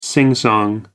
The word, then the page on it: singsong: American English